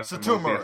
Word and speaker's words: Satomura: Do you think we'll see my other favorite wrestler, the female Goto, Mako Satomura? Is that how you say her name? Because I'm bad with these. But you basically got it. You got it better. – Satomura. Satomura.